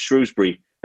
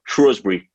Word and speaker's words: Shrewsbury: The main thing that's Joe's clear up for me is it's pronounced Shrewsbury and not Shrewsbury, as I've been saying it this whole time.